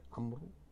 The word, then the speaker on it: عمرو: ولكننا نستطيع أن نضرب المثل هنا عفوا بالحروف التي لا تنطق كالواو التي في عمرو، والحروف التي لا تكتب كواو المد في داوود، والحروف التي تنطق ويكتب رمز غيرها من الحروف كالألف في رمى وهل مجرة.